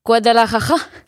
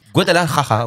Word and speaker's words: Guadalajara: Non, c'est une des villes les plus compliquées à prononcer. Guadalajara ah oui, Guadalajara, oui, exactement. – Voilà, magnifique. Avec une collaboration 100% euh,